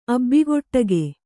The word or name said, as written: ಅಬ್ಬಿಗೊಟ್ಟಗೆ